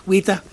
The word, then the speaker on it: Weta: Yeah, because this is all the effects, by the way, ladies and jelly spoons, is Weta, or Weta Workshop, I pronounce it like New Zealanders, Weta Workshop.